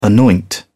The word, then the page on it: anoint: British English